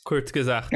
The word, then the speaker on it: Kurzgesagt: Tem um canal maravilhoso que eu não sei pronunciar, eu acho que é Kurzgesagt. – Kurzgesagt.